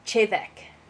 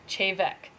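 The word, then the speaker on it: Té Bheag: It looks like Tea Bag, but it's actually pronounced Chevek. – Chevek.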